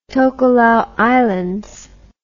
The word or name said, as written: Tokelau Islands